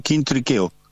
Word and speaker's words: Quintriqueo: la Kinsikeu, digo bien, o Kinsikeu, ¿cómo tengo que decir? – Es Melo y Kinsikeu. se escribe, Quintriqueo. Se escribe de una forma y sí. se pronuncia de otra.